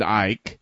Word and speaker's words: Icke: The first one they have on the list is David Icke. – Or could be pronounced Icky, depending on how well you like it.